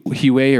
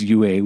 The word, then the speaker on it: Huet: But, anyway, so fast forward, and then, you know, with you, Huey, or he was, I guess it was UA. We were all saying it wrong.